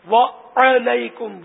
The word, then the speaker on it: وعلیکم: اچھا, عین کو زبر پڑھنا ضروری ہے, وعلیکم وعلیکم.